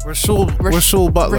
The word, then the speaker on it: Rasual Butler: Is it Razul? – Rasul Butler.